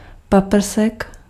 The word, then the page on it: beam: /biːm/